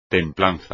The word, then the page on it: templanza: espagnol